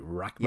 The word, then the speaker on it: Rachtman: Rachtman.